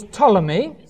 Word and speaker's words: Ptolemy: The second one was Ptolemy, spelt with a P and a T. P-T-O-L-E-M-Y.